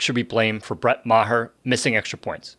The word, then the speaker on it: Maher: No, it's not pronounced Maher. It's pronounced Maher. – Right. Like, I'm going to martyr you after the show.